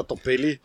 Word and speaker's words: Dobelli: Der ist oft falsch ausgesprochen, weil er da zwei L hat und dann sagen die Leute Rolf Dobelli. – Der Dobelli. – Der Dobelli.